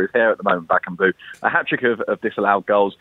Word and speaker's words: Cedric Bakambu: And just finally, Cedric Bakambu, is that how I pronounce it? – Bakambu.